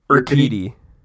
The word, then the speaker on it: Urquidy: How do you say his last name? – Urquidy. – Urquidy.